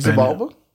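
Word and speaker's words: Xibalba: Chibalba? – Yeah, yeah. I say Chibalba. – Oh, yeah. I was like, you never heard it pronounced that way. Yeah, I've always said it like that, too.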